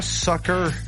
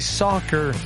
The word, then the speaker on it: soccer: C'est pas soccer. – Non, c'est pas S-U-C-K-E-R. – C'est soccer.